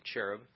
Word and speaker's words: cherub: You were the anointed cherub, that's the English pronunciation of cherub, who covers.